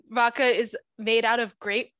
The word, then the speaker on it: rakı: Okay, so I'm going to talk a little bit about rakı, which is the national drink of Turkey, spelled R-A-K-I, pronounced rakı. So, you know, I've heard a lot of people pronounce it rakı. – Well, it's because it has the weird I without the dot.